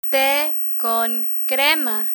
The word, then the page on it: te con crema: spanish